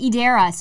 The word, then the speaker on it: Edera: Andrew from Edera, which he pronounced it Edera. So I'm like, okay, that's how we okay. pronounce it now.